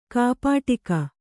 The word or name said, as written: ಕೈಪೆಟ್ಟಿಗೆ